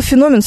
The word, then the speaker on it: феномен: У слова «феномен» совершенно точно одно ударение. Это на букву «о».